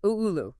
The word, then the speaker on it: Oulu: It's pronounced Oulu.